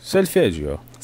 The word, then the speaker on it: Solfeggio: Solfeggio. That's how I pronounce it. I'm pretty sure it's Italian. – Yeah. Solfeggio.